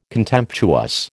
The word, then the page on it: contemptuous: American English